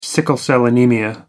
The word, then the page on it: sickle-cell anaemia: American English